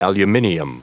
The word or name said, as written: aluminium